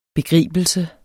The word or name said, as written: begribelse